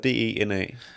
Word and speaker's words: DeNA: Nå, ja, jeg tror, firmaet hedder DENA. – Ja, jeg tror, det vil udtales DNA. Det er i hvert fald, hvad jeg har hørt, men ja.